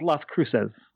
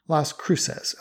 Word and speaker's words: Las Cruces: How do you pronounce that? – I'm going with Las Cruces. – Las Cruces, okay.